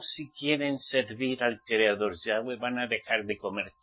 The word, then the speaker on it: Yahweh: Y hay que pronunciar Yahweh, no como viene escrito YHWH, porque es YHWH. Yahweh.